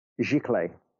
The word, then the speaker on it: giclée: Now, some people call them giclée printers, which is a term that I've heard was invented to separate people's perceptions of inkjet printing and then archival. – Can you say that word again? – Giclée. – How do you spell it? – G-I-C.